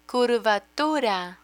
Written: curvatura

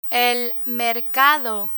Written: el mercado

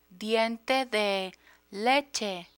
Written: diente de leche

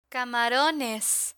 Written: camarones

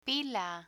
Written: pila

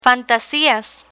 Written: fantasias

